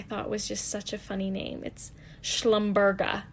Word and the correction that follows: Schlumbergera: Oh, I said it wrong. It's Schlumbergera. Schlumbergera. Yeah, not Schlumberger. I guess that's it's an oil company.